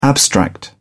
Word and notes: abstract: British English

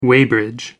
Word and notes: weighbridge: American English

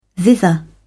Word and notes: thither: British English

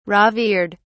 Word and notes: revered: British English

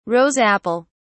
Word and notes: rose apple: British English

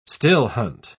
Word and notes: still-hunt: British English

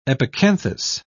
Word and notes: epicanthus: British English

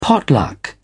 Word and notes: pot luck: British English

